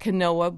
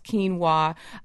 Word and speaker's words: quinoa: Looks like quinoa, but pronounced quinoa, pearl barley.